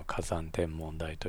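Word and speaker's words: 花山天文台: で 1204 年に、京都でオーロラが見えたと言われているのもその現象の結果ではないかというふうに、言われているんですが、それはずっと研究されている京都の火山天文台、まあ、山花と書いて火山天文台と読むんですけれども、まあ、そこの台長の先生にお話を伺ったことがありまして、でその太陽の爆発フレアっていうのは、まあ、いつ起こるかわからないけども、起こったら、結構大変なことになると。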